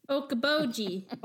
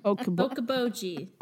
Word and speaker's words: Okoboji: Okoboji. Okoboji. – Okoboji, of course.